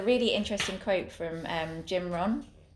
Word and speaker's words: Rohn: Well, it's spelt like Ron, but anyway. – Okay. Rohn. Isn't it R-H-O-N? – R-O-H-N.